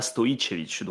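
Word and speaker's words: Stojičević: En français, tu vas plutôt dire Stojicevic, et après, en bon serbe, tu diras Stojicevic. Donc, t'as le J qui se prononce I.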